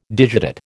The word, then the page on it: digitate: American English